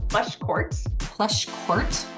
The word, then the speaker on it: Pleschourt: Pleschourt.